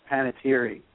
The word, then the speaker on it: Panettiere: Her name is, I think her name is pronounced Panettiere.